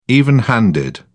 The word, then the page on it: even handed: British English